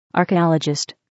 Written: archaeologist

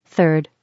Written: 3rd

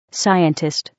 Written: scientist

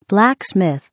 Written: blacksmith

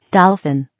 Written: dolphin

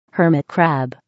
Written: hermit crab

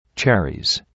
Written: cherries

